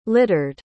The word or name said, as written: littered